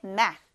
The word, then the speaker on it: math: TH 발음 유의해서 math, 이렇게 math 발음합니다.